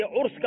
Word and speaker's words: عرس: کسی بزرگ کی سالانہ فاتحہ کی جو مجلس ہوتی ہے عرس کہا جاتا ہے, عرس نہیں ہے.